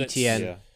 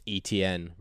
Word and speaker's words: Etienne: You're going to get annoyed with how it's said, but it is said Travis Etienne rather than Etienne, even though it's spelt how Saint Etienne, the French place, is spelt.